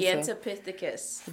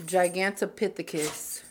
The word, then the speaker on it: Gigantopithecus: Did I say it? – Yeah, I think that's how you say it. – Gigantopithecus. – I could be saying it wrong too. Fuck, I don't know. Hold on. Let's Google. – Let me Google Plesiosaurus. – Oh, no. I am getting it right.